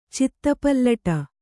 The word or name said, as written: ಚಿತ್ತಪಲ್ಲಟ